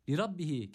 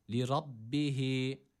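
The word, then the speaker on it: Lirabbihi (لربه): Lirabbihi kelimesinde yine ra kalın okunuyor burada. Üstün bir hareke almış. A sesi veriyoruz. Lira. Lirabbihi. Lirabbihi. Şeddeli olan B'yi tutmuyoruz. Sadece iki kez okutup geçiyoruz. Ve yine H'yi burada bir elif miktarı uzatıyoruz. Lirabbihi.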